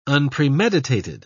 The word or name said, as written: unpremeditated